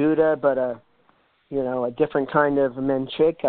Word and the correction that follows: manchego: or um, manchego.